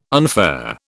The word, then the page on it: unfair: British English